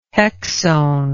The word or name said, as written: hexone